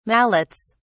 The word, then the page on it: mallets: British English